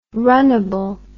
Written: runnable

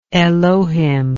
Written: elohimic

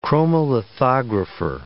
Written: chromolithographer

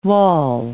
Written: wall

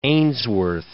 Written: Ainsworth

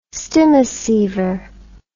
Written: stimoceiver

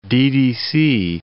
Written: ddC

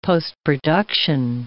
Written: postproduction

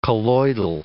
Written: colloidal

